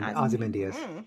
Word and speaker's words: Ozymandias: Fly 51 and Ozymandias. Is that how you say it? – Ozymandias.